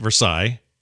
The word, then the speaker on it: Versailles: Versailles, yeah.